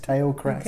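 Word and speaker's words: thale cress: Or thalecress, T-H-A-L-E, thalecress, tailcress, okay.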